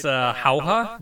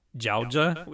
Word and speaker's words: Jauja: It's Jauja, but it's spelled with a J. Jauja.